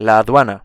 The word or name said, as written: la aduana